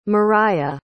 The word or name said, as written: Mariyah